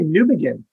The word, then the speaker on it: Newbegin: Am I saying that right? – It's actually Newbegin. – Newbegin, like new beginning.